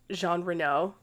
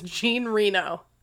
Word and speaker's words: Jean Reno: Side note, I know that his name is pronounced Jean Reno. But every time I see it, I'm like, his name is Gene Reno. That man, he is Gene Reno.